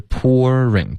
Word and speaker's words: pouring: When the offers were pouring in, 后面就没有变化了 okay, 可是 pouring, 大家注意一下有两种发音 okay, 第一是 pour, 结尾加一个儿化音 pour 然后再来 ring pouring OK 还有一个呢是 pour 就开头是一个 o